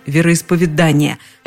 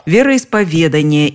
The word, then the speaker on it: вероисповедание: Орфоэпический словарь под редакцией Аванесова все-таки упоминает, что встречается вариант вероисповедания – но рядом с ним стоит строгая помета «неправильно». Честно говоря, по моим наблюдениям, людей, которые произносят тот самый неправильный вариант, несколько больше, чем говорящих по правилам. Но словари пока стоят на своем. Вероисповедание и только так.